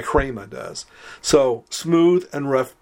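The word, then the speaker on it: hrema (ῥῆμα): The word for word or utterance is pronounced hrema. You start with an H, but then you say R.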